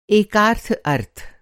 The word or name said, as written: एकार्थ